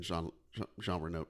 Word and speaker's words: Jean Reno: or even Jean Reno. Am I saying that right? – Yeah. – Okay, good.